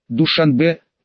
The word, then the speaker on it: Душанбе: Душанбе произносится с ударением на последнем слоге, можно назвать самой красивой столицей в Центральной Азии.